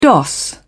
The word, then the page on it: doss: British English